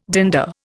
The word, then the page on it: dander: Indian